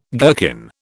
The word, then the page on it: gherkin: British English